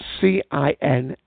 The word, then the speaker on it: Cinsault: It tends to lack acidity, tannin or colour, with and is usually blended with other varieties such as Syrah, Cardigan, Tempranillo, and Cinquante. And I pronounced that one, I'm sorry. That's Cinsault.